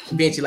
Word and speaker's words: Vinci: We're going to say Vinci. Vinci, like Da Vinci.